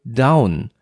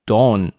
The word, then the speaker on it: Dawn: Ich weiß nicht, ob dir das mal untergekommen ist, aber es gibt auch sehr viele Leute, die sagen From Dusk Till Down. Also runter anstatt Dawn. Das ist ja was anderes. Ist es mal aufgefallen, dass viele Down sagen statt Dawn? – Ähm, ich sag From Dusk Till Tom.